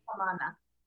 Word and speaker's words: Pamana: Now you're working with Pamana, and I hope I'm saying that right. Pamana LTD or Limited. Say it again for me. – Pamana. – Pamana, thank you. I know I had the acento different.